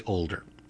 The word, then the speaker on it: Older: You, we need to tell the listeners, are Daniel Jose Older. – That's me. – And all of those are basically as written, older, as in younger, older, Jose.